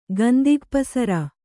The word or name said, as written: ಗಂದಿಗಪಸರ